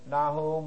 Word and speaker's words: Nahum: So put a dot underneath it, but what I started to give you was N-A-C-H-U-M, Nahum. But we pronounce it Nahum with the soft H, and that's how it comes down.